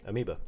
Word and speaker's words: Amoeba: Amoeba.